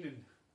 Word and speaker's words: Dinan: How do you pronounce it? – Dinan. You pronounce it wrong the entire time. Dinan. – Dinan.